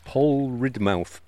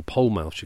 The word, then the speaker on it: Polridmouth: Pole Ridmouth, or Pole Mouth, you call it. – Pole Mouth, I think the locals may pronounce it that way. Yeah. But it's spelt Pole Ridmouth Bay.